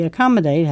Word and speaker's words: accommodate: While recommend only has 1 C, accommodate has 2 of both consonants. And the word second O doesn't even make an O sound. Accommodate, day. Yeah.